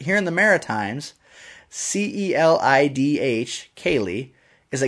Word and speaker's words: Celidh: My aunt knows a woman who named her daughter Kaylee. K-A-Y-L-E-E. Well, that's how it's pronounced anyway. It's actually spelled C-E-L-I-D-H.